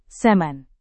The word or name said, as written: semen